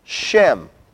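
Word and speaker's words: shem (שם): In Hebrew, the term name, shin mem, is pronounced shem.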